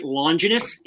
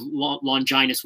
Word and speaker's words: Longinus: Well, when I was a kid, they always pronounced it Longinus in the old ADV dub. And in this new dub, they pronounce it the lance of Longinus, which I believe is actually how it's pronounced.